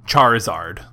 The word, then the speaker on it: Charizard: Well, it's a Charizard. – Oh, yeah. Oh, right. A shiny Charizard Pokemon card. – Is it Charizard or Charizard? – Charizard. Like you're charring the grill.